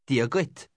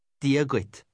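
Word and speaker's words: Dia dhuit: Dia guit. Diaguit.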